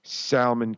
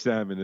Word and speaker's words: salmon: giant, smelly, salmon cunt. – You even pronounce the L in salmon. – Oh, it's salmon, isn't it?